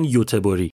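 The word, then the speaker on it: Göteborg: مثلا گاتنبرگ انگلیسیه خود سوئدیا میگن یوتبوری یا یه چیزی تو همین مایه ها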